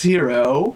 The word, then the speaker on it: Hiro: Check yeah. It's Hero. – I've also watched multiple animes where it's spelled that way and it is pronounced Hero.